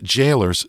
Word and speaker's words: Gaolers: And that's Jailers spelled in the Old English style, G-A-O-L-E-R-S. And yes, I had to look that up before I could pronounce it.